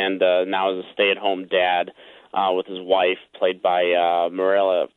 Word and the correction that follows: Mireille: How do you pronounce her name? – Mireille. – Mireille Enos from The Killing is his wife and his two young daughters.